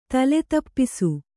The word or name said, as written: ತಲೆತಪ್ಪಿಸು